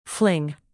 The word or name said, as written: fling